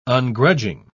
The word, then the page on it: ungrudging: British English